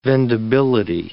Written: vendibility

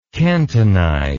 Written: cantonize

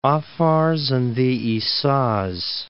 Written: French Territory of the Afars and the Issas

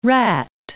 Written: Rat Islands

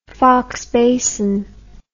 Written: Foxe Basin

